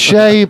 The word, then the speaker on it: Che: I think it's pronounced Shay. It's actually pronounced Shay Guevara. Not Che. – Che Guevara. Hey, Sherard. Yes, Kurt. – It's pronounced Shay, but all right.